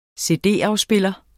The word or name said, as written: cd-afspiller